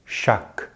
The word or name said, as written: chaque